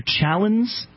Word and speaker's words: Challand: Andrew Challens? – Yeah. Challen. – Spell that. – C-H-A-L-L-A-N-D.